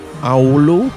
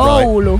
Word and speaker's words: Oulu: You're from Oulu? Have I pronounced that Oulu. – Right? – Oulu.